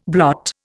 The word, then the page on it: blot: Indian